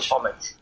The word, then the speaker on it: homage: How do you pronounce that word? Would you say homage or homage? – Homage. No, homage. Homage. Like fromage.